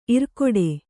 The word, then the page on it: ಇರ್ಕಡೆ: Kannada